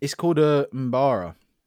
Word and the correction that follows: Mbira: Mbara? How do you say that? How do you pronounce that? It's from Zimbabwe. – Kalimba. – Kalimba. – Oh, what the fuck? – Obviously. Yeah, you've seen that before. – Mbira. – Mbira? Mbara? Mbira? – Oh, I don't know.